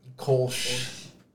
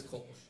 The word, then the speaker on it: Kölsch: Kolsch. I'm pretty sure that's how it's said. – It's Kolsch.